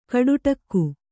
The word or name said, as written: ಗೋಟಡಕೆ